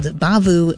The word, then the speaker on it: Bavhu: And actually, in between all those was the Boogaloo, aka Fiesta, with the band that is entitled Bavu, but it's spelled B-A-V-H-U and it's capitalized.